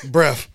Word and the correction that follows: breathe: It's that a breathe. – Oh, my, God. – It's pronounced breathe.